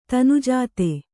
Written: ತನುಜಾತೆ

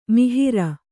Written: ಮೊಹರೆ

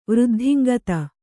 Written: ವೃದ್ಧಿಂಗತ

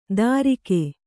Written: ದಾರಿಕೆ

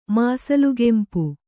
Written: ಮಾಸಲುಕೆಂಪು

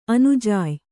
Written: ಅನುಜಾಯ್

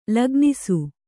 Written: ಲಗ್ನಿಸು

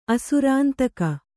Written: ಅಸುರಾಂತಕ

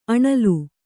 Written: ಅಣಿಲ್

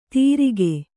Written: ತಿರಿಕೆ

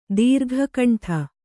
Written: ದೀರ್ಘಕಂಠ

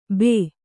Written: ಭೂ

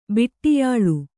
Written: ಬಿಟ್ಟಿಯಾಳ್

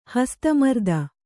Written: ಹಸ್ತಮರ್ದನ

